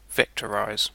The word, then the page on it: vectorise: /ˈvɛk.tə.ˌɹaɪz/